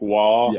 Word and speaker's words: Wahl: Is that spelled like W A H L? Like wall? – Yeah, Waluigi?